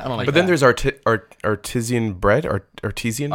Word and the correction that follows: artisan: Artisan bread.